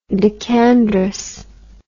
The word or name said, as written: decandrous